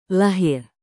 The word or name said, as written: lahir